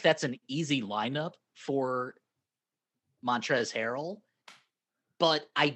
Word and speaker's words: Montrezl: Montrezl Harrell. Don't forget that silent L in there.